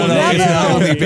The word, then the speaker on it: Navalny: Navalny, baby.